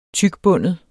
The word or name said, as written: tykbundet